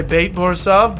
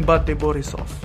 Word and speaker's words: BATE Borisov: I know a lot of people say Bate Borisov, but I looked up the Russian pronunciation and I realized Russian and Belarusian is not the same language, but they share a lot in common, so I'm saying Bati Borisov.